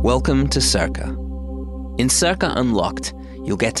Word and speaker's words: Cerca: In Circa, that's spelled C-E-R-C-A,